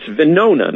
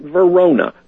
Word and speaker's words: Venona: It's Venona, not Verona, but Venona.